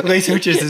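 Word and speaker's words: Les Houches: So there's this town, and in my head, I call it Les Hooches. – Yeah, Les Hooches. – Les Hooches, and that's 100% not the French pronunciation. – Les Hooches is perfect.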